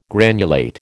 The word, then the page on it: granulate: American English